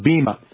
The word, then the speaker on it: bema: The Greek word is bema. We pronounce it very often bema.